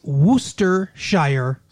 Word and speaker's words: Worcestershire: Even though it's spelled like Worcester, it's pronounced Worcester. So that sauce is Worcestershire sauce.